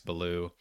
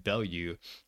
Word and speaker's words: Ballou: Oh, and by the way, when we talk about Max Ballou, huge, huge disappointment for me to learn that it's pronounced Ballou, and I've been saying Bellew all off season, but it is in fact pronounced Ballou.